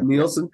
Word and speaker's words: Nielsen: Is that how you pronounce it? – I think it's Nielsen, I think. Yeah. – Nielsen.